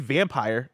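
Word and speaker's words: Vampyr: I think it's pronounced vampire. – No, it is. I don't like to call it vampire because there's a Y.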